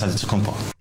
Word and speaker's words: Antetokounmpo: Just when you got used to saying Tua Tagovailoa correctly, in comes Giannis Antetokounmpo, our Miami Heat player, and there's a couple ways you can pronounce his name correctly. He even says, Whatever you got, just say, you know, it's Giannis Antetokounmpo.